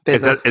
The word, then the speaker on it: Bezos: How do you say it? Bezos or Bezos? – Bezos.